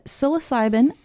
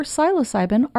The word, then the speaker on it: psilocybin: And, fun fact, in researching this, I found out that psilocybin or psilocybin are both acceptable pronunciations.